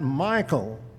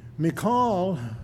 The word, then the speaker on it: Michal: Her name was, and I will spell it first, M-I-C-H-A-L. Everyone pronounces that Michael, but during her lifetime, no one ever said it that way. She would not have known what you were talking about if you had called her that. The proper Hebrew pronunciation of her name is Michal, which is just as easy to say as Michael. Michael's a boy's name. She was Michal, and that's what I'm going to call her the rest of the time tonight.